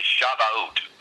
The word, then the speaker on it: Shavuot: The Hebrew word is Shavuot. That's the way that the Jewish people pronounce it,